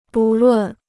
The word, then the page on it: 不论: bù lùn